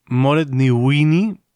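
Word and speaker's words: Mairéad Ní Mhaonaigh: Moret Ní Uinni, pronunciación aproximada en irlandés, porque desde luego de cómo está escrito a cómo se pronuncia hay una diferencia enorme,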